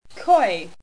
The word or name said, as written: Koi